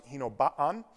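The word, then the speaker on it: Hinoba-an: I know I sound funny when I'm saying that, but that's how they pronounce it, Hinoba'an.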